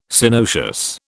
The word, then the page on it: Synoecious: British English